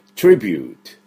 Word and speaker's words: tribute: で、 BUTE、これはサイレント E の法則がですね、働いてまして、普通だったら BUT ですから、ブテって呼ぶところ BUTE、 BUTE って呼びます。 E を発音しません。その 2 つ前の母音が U というですね、アルファベット U に戻ると U ではなくて U になってます、ね、で、最初の TRI とくっつくと TRIBUTE という音になるんですね。